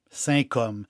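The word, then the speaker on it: Saint-Cosme: C-O-S-M-E, si ça se prononce comme. Saint-Côme.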